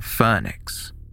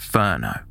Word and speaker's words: Furneaux: Although the caller misspelt the name Hollyhock, using H-O-L-L-Y-C-O-C-K, he knew to correctly pronounce the name of the village, saying Phoenix. Outsiders often referred to the area using the French pronunciation of Ferno. When the caller was asked how to spell Phoenix, he spelt it F-U-R-N-E-A-U-X.